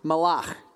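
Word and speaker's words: malach (מלאך): This is Hebrew, and it's pronounced malach, okay?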